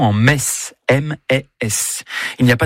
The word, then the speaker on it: Metz: Le meilleur moyen de mémoriser la prononciation correcte de la ville de Metz est certainement de se rendre au stade Saint-Symphorien, où vous répéterez jusqu'à plus soif.